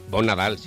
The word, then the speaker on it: Bon Nadal: Lo he dicho bien, ¿no, Bonilla? Bon Nadal. – Bon Nadal, sí. – Bon Nadal, se dice.